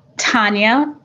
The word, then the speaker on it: Tania: Sometimes instead of saying Tania, I say Tanya. How do you pronounce your name, Tania? – Tania, but the way it's spelled, I respond to all translations.